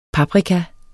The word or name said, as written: paprika